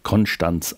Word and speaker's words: Konstanz: Gebhard, der zweite Bischof von Konstanz oder lokal vor Ort Konstanz ausgesprochen, wahlweise auch von Bregenz, geboren am 7.